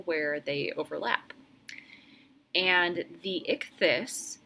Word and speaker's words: Ichthys: In my research of Pisces imagery, I also encountered something called the Vesica Pisces and the Icthys. That's spelled I-C-H-T-H-Y-S.